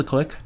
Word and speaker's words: Kliq: Are they called the Click? – Yeah. – Okay. If they are, then 21 Pilots got it from wrestling. – Okay. Possible. – But it's spelled K-L-I-Q, because they're cool.